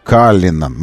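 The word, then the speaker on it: Cullinan: Каллинан в Роллс-Ройсе, в российском офисе Роллс-Ройс, настаивает, что произносить это нужно Каллинан. Мы знаем, что Каллинан это, ну, это бриллиант.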